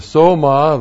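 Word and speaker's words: soma (σῶμα): And the soma, that's S long O M A.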